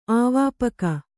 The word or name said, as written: ಆವಾಪಕ